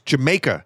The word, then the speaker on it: D'yer Mak'er: Now, the title of this next song is actually pronounced Jamaica, as spoken by the locals in that country. It's a play on the phrase, Did You Make Her?